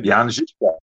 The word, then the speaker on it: Jan Žižka: Jan Žižka, yeah, Jan Žižka.